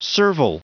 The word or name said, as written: servile